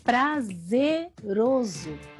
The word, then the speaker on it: prazeroso: É prazeroso servir a Deus. Concordo. Mas vamos melhorar essa pronúncia? Diga que é prazeroso. Vem de prazer.